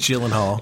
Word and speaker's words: Gyllenhaal: Love and Other Drugs featuring Anne Hathaway and Jake Gyllenhaal. God, I hope I said that right. – Gyllenhaal.